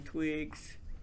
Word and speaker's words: Twiggs: Marcia Ann Twiggs, T-W-I-G-G-S.